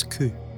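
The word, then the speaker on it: ku (九): 4 can be pronounced as shi and 9 as ku.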